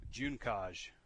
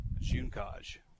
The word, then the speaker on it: Junkaj: Junkaj. I asked him, and he sent me a voice recording of himself saying it. Junkaj. It's like the month of June.